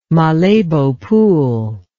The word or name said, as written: Malebo Pool